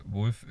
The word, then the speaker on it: Wolfe: Ähm, sind komisch geschrieben, also Chelsea, so wie Chelsea halt, und äh, Wolf, W-O-L-F-E. Deswegen muss ich gerade auch echt gucken, wie man die ausspricht. Ich hoffe, ich habe es jetzt richtig ausgesprochen. – Ja, hast du.